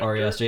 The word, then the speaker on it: Ari Aster: Ari Aster.